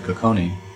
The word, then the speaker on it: Cocconi: and that's the paper you did with Cocconi. Is that how you pronounce it? – Yes.